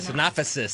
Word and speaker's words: synopsis: the synopsises. – The what? – The synopsis. – Synopsis. – Synopsis is the correct pronunciation.